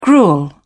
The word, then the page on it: gruel: British English